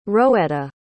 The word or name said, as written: roetta